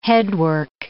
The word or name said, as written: headwork